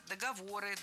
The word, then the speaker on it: договоры: договора составлены правила. – Договора. Неверно. Все та же ошибка. Передвинули ударение, а этого делать не стоило. Правильно, договоры, договоров, договорами, договорах и так далее.